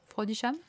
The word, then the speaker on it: Frodisham: Je pense qu'on prononce comme ça Frodisham Oui, Donc le professeur Frodisham, soi-disant spécialiste du monstre, qui euh, notamment l'aide à convaincre le roi d'instaurer la taxe Ikabog.